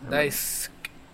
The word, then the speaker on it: Daisuke: Entonces, lo chulo es cómo surge, porque era un artista japonés, un cantante, que se llamaba Daisuke Inoue. No sé si lo pronuncié bien, no sé japonés. – Daisuke.